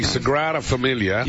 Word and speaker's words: Sagrada Familia: The Sagrada Familia.